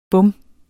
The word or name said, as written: bum